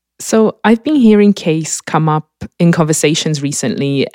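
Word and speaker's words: Kaes: No, it's Case. It's like a case for your body.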